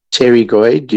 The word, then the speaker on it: pterygoid: It's spelled E-T-E-R-Y-G-O-I-D, pterygoid. You don't say the P, otherwise you'll spit on someone.